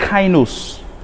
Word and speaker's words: kainos: New tongues is the word kainos. Yeah, kainos is spelled K-A-I-N-O-S.